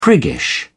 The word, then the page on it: priggish: British English